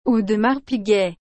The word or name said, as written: Audemars Piguet